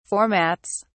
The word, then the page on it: formats: British English